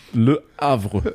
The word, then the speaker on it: Le Havre: Und wir gucken nach Frankreich, genauer gesagt zum französischen Erstligisten AC Le Havre. Ich weiß jetzt nicht, ob ich ihn richtig ausgesprochen habe. – Hast du schön gesagt. Le Havre.